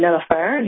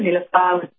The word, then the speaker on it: Nilufar: And so my name, Nilufer, Nilufar, how you say it in Persian, means lotus, like the lotus flower.